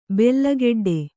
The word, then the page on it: ಬೆಲ್ಲಗೆಡ್ಡೆ: bella geḍḍe